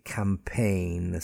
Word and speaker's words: campaign: Align, A-L-I-G-N, align, no stress again on the G. And finally, campaign, C-A-M-P-A-I-G-N. Again, no stress on that G.